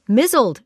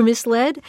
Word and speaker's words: misled: My mispronounced word, which launched the series, was mizzled for misled.